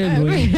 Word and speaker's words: De Bruyne: De Bruyne no se dice De Bruyne, pero todos decimos De Bruyne o De Bruyne.